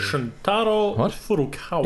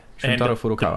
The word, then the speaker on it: Shuntaro Furukawa: Shuntaro Furukawa. – Yes. Yeah, Shuntaro Furukawa.